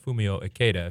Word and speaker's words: Fumio Ikeda: or Fumio Ikeda, if you're pronouncing it the correct Japanese way.